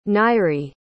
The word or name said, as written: Nyree